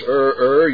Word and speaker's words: err: Number one, if you err, and that's the proper way to pronounce E-double-R, if you do not pronounce err, you are in error.